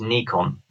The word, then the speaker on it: Nikon: Well, so English people say Nikon. Americans say Nikon. The actual correct pronunciation is Nikon. So that apparently is the correct pronunciation.